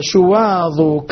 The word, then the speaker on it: شواظ: لاحظ البيت اللي بعده: ظاهر لظى شواظ كظم ظلما، أغل الظلام ظفر انتظر ظما. نفس الشيء، كلمات ذكرها ابن الجزري كلها تنطق بالظاء وليس بالضاد، بالظاء وليس بالضاد.